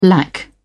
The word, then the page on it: lack: British English